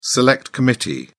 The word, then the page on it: select committee: British English